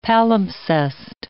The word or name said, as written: palimpsest